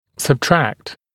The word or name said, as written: subtract